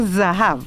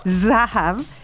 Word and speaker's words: Zahav: Hoy día vamos a aprender la palabra Zahav. Z-A-H-A, eh, B corta en fonética, Zahab, que, quiere decir oro.